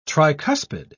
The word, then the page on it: tricuspid: British English